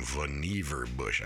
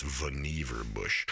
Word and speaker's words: Vannevar: So now I'm really reading Endless Frontier, Vannevar Bush, that's how you pronounce his name, Vannevar Bush. I always thought it was Vannevar Bush, and so did everybody else in his life, apparently, but it's Vannevar Bush.